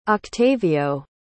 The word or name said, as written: octavio